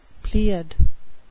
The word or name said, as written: pleiad